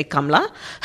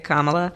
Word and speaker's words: Kamala: If you were asking my grandmother, she'd say Kamla. I usually help people pronounce it by saying, well, just think of the hyphen, a comma, and then add a la at the end, and that's it, Kamala.